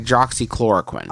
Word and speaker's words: hydroxychloroquine: Okay, tell me if I'm saying it right. Is the chloroquine? – Nope. – See, I didn't even get hydroxychloroquine. – Close. Hydroxychloroquine.